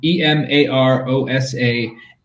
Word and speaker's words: Emarosa: It's pretty, you know, E M A R O S A, Emarosa. That's how you say it.